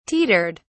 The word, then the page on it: teetered: British English